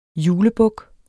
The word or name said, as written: julebuk